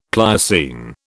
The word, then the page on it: Pliocene: British English